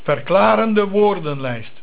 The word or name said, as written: lijst